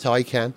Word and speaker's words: Taycan: I know it's pronounced Taycan.